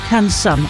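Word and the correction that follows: Cancún: You mean Cancun, don't you? – Maybe so. Yeah, Cancun.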